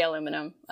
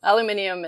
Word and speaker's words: aluminum: No, we say aluminum. Aluminium is everywhere else, all the other Commonwealth countries except for Canada.